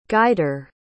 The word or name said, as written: guider